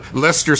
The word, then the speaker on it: Leicester: Leicester Square, for instance. – Yeah. It's got C's in there and E's and I's. – Yeah. Well, let's spell it out. It's L-E-I... – C-E-S-T-E-R. – C-E-S-T-E-R. – And all it needs to be is L-E-S-T-E-R.